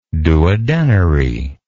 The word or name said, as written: duodenary